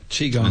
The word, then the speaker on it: Qui-Gon: Qui-Gon in the first three Star Wars films.